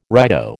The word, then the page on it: righto: American English